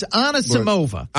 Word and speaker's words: Anisimova: It's Anisimova.